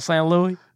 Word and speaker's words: St. Louis: How do you say the word S-T-L-O-U-I-S? How would you say that? – What? – S-T-L-O-U-I-S. How would you say that out loud? – Oh. – Saints? – Yeah. – How'd you say it? – St. Louis? – There you go.